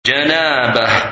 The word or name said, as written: Janabah